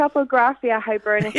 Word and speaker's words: Topographia Hibernica: But the name of the book, go on, pronounce it's it. Topographia Hibernica. – Yeah, you got it.